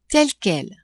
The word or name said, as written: tel-quel